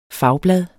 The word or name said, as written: fagblad